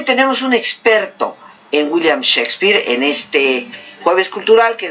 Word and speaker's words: Shakespeare: Pero en realidad no se dice Chaquespeare, se dice Shakespeare.